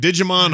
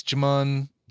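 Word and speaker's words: Djimon: It's, I don't think you pronounce the D. I think it's Jimon Hansu.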